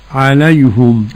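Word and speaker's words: عليهُم: أن عليهم ضم الهاء من عليهم حمزة ويعقوب. – نعم. تنطق كيف فضيلة الشيخ؟ – عليهم.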